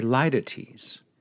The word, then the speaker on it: litotes: Well, this is called a litotes. L-I-T-O-T-E-S. Litotes. That's how you pronounce it, according to the dictionary, when I click on my little pronouncer.